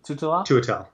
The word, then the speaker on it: Tuatel: Tutala, how do you say it again? Tutala? – Tuatel. – Tuatel, sorry. You got to say every vowel in these names. Like, script. Yeah. Every vowel's important. I should know, because I can say Starlo Tutale very well, because I know that one quite well.